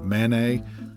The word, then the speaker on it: Manet: But if you ask me to compare, let's say, Monet with Manet, the only thing I'm pretty sure of is that you're not supposed to pronounce the T in either one.